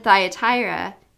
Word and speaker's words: Thyatira: And then Thyatira, did I say that right? – Yeah.